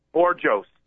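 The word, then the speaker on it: Bourjos: And his name is Peter Bourgeois? – Borjos. You actually pronounce it like when you read it and you look at it. You don't have to think French or anything. You just go Peter Borjos.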